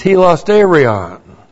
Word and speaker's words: hilasterion: The word there, sacrifice of atonement, is hilasterion. Looks like that in the Greek. That's H-I-L-A-S-T, long E-R-I-O-N.